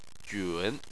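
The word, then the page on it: jun: tone 3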